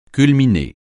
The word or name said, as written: culminer